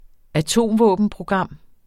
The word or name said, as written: atomvåbenprogram